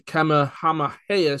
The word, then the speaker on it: Kamehameha: He is the assistant controller at the Kamehameha School. Yes, I pronounced that correctly, in Hawaii.